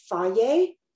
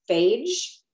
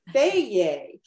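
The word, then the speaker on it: Fage: You know, if you think about like that yogurt brand, Faye, right? It's spelled like it should be pronounced phage. Right? Or fodge, or, you know, everyone's like fodgy, you know, what is it? Faye.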